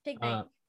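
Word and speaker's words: Pignite: So, this here is PG Knight. PG Knight? – Pig Knight. – Pig Knight. You're right. Sorry.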